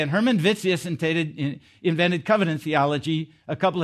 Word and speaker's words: Witsius: This book here, The Economy of the Covenants, is written by a guy named Hermann Witsius. This is German, so the W is pronounced like a V.